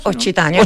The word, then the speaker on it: Occitania: Eh, bueno, finalmente puedo decir que Marta Rébora creó ese mismo año la empresa Occitania, ¿está bien pronunciado? – Occitania, Occitania Patrimonio.